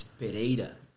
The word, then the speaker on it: Pereira: You would say in Portuguese, really, if you really want to stress it. – Yeah, it's Pereira.